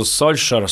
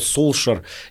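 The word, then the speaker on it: Solskjær: А есть знаменитый пример про нынешнего тренера Манчестер Юнайтед, который пишется по-норвежски «Сольскьяер» с лягушкой, а произносится он ну, что-то между «Сольшер», Сол, «Солшер».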